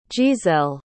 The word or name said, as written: jeaselle